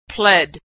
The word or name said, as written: pled